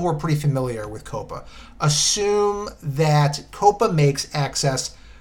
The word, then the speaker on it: COPPA: There is a law called the Children's Online Privacy Protection Act, or COPPA, C-O-P-P-A.